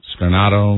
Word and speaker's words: Scarnato: The Haley Scarfato? – Scarnato? – Scarnato.